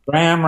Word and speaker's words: Bram: Bram rhymes with ham.